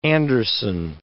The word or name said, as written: Anderson